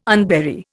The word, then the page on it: unbury: Indian